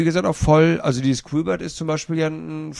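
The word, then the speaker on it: Q*bert: Klassiker gibt es halt nur einen, das ist dieser Quibert. Ich hoffe, der wird das ausgesprochen. – Quibert. – Ja. Quibert. Q-Bert.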